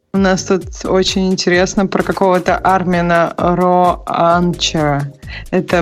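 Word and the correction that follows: Ronacher: Ронахера, я да? думаю, тебе... – Ронах... А, все-таки не Че, там Хэ читается?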